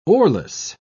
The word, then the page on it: oarless: British English